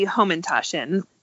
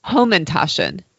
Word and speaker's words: hamantaschen: Probably Home and Toshin. – Home and Toshin. – Yes. – Okay. – Some people do pronounce it Home and Toshin, but I grew up pronouncing it Home and Toshin.